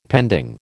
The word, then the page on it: pending: American English